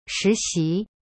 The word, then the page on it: 实习: shi xi